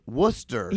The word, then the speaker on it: Worcester: It's like Worcestershire, like nobody knows really how to say it the right way.